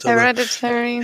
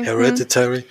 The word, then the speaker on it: Hereditary: Und ich finde, bei dem Regisseur merkt man ist für mich genau das gleiche wie bei Hereditary. Ich weiß immer nicht, wie das ausgesprochen wird. – Hereditary. – Hereditary.